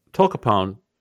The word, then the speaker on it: Tolcapone: Tolcapone. And we hope we're saying it correctly because this is the first time I've ever heard of this drug. But that it's spelled T-O-L-C-A-P-O-N-E. Tolcapone is what we're saying.